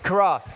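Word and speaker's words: Chara (χαρά): And so it's this Greek word, and Jeff, correct me if I'm wrong in pronouncing this, okay? Chara? Boom, got it. Chara, all right?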